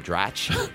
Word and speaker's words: Dratch: I think you pronounce her name Dratch.